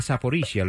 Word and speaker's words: Zaporizhia: Zaporizhia.